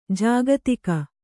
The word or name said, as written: ಜಾಗತಿಕ